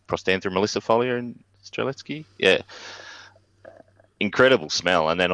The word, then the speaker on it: Strzelecki: But I love that genus actually, and I have a really distinct memory of being at the, and I'll have to pronounce this right because I have probably two Polish women sitting at home listening to this at the moment. So the Strzelecki Ranges, which I don't think is how most Australians pronounce it, same as Kosciuszko.